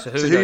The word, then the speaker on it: Cejudo: So anyway, Cejudo? – Cejudo, yeah, Henry Cejudo.